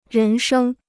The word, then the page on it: 人生: rén shēng